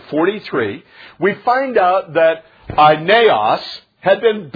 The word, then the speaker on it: Aeneas: And a man named, it looks like Aeneas, and it's really pronounced Aeneas. Can you say it with me? Aeneas.